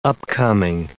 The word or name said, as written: upcoming